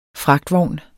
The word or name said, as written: fragtvogn